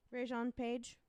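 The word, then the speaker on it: Regé-Jean Page: Rajon Page.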